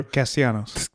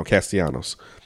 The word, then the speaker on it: Castellanos: to Nick Castillo or whatever. – Castellanos. – Thank you. I knew I was saying it wrong. Castellanos.